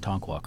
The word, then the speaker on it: Tonkawa: Thank you for pronouncing Tonkawa correctly. Good job.